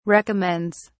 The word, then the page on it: recommends: British English